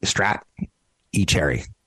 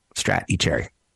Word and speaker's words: Stratechery: He's with Stratechery, So think of it as ST Strat E Cherry, Strat E Cherry. So it's kind of like George Bush would say strategery. And you'd be like, think you just butchered the word strategy, strategery.